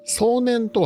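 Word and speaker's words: 送念: 壮年とは送る年と書いて壮年と呼んでおります。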